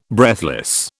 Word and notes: breathless: British English

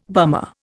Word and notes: bummer: Indian

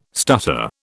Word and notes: stutter: British English

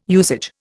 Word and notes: usage: Indian

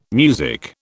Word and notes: music: British English

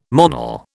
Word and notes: Monel: British English

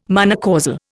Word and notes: monocausal: Indian

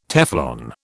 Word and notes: Teflon: British English

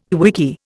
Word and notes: quickie: Indian

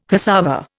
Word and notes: cassava: Indian

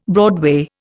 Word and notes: broadway: Indian

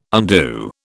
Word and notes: undo: British English

